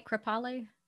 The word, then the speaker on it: Kripalu: And I read that you were reading through the Kripalu. Am I saying it right? Kripalu? – Yeah, that's right.